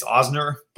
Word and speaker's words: Ozner: It's Ozner, but um, Ozner.